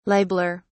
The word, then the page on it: libeller: British English